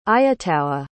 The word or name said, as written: aiattaua